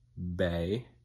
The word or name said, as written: bay